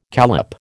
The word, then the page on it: callop: American English